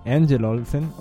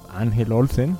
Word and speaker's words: Angel Olsen: Esta es Angel Olsen, o Ángel Olsen. Angel es en inglés, si prefieren la pronunciación anglosajona.